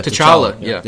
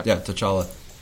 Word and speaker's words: T'Challa: How do they actually pronounce it? Because they never call him Black Panther in the comic. – Oh, so his name is... – T'Challa? – Yeah, T'Challa. – T'Challa.